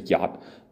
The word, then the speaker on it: jot: Every jot and every tittle. I know I pronounced jot wrong. It's supposed to be yot, but that's okay. I'm not Jewish, I'm American. I say jot.